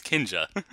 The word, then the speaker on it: Kinja: But it's I don't care what Kanji's selling me. I don't care about Funko Pops. I'm tired of it. – Kanji, it's Kinja.